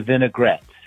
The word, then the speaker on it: vinaigrette: The correct pronunciation of the salad dressing is actually three syllables, not four. It's a vinaigrette.